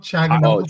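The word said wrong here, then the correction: Çalhanoğlu: Chalanoglu. – There we go.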